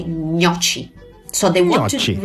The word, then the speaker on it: gnocchi: So the correct way to pronounce gnocchi is this. Keep pushing your tongue. Gnocchi.